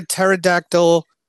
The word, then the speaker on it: pterodactyl: Do you know why you can't hear a pterodactyl go to the bathroom? Because the P is silent.